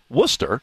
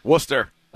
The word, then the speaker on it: Worcester: And I'm looking at our roster here with our pronunciation in a nice city called, it's pronounced Worcester. – Worcester. – Massachusetts. – You hear a lot of different variations of that. – You sure do. But I like that it's phonetically spelled out for us here.